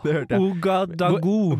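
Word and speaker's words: Ouagadougou: Ouagadugugu. Nei, det var én G for mye.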